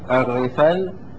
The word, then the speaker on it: الرفل: وفي الرفل بكسر الراء وفتح الفاء وتشديد اللام، الرفل وهو الفرس الذيال، الذيال يعني طويل الذيل.